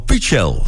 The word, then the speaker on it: Pichel: Danny Vera en Marlon Pichel. Zo spreek je die naam uit. Ik heb de afgelopen tijd op de radio, Nederlandse radio, die achternaam van Marlon op verschillende manieren gehoord. De meest komische uitvoeringen. Pichel en Pichel.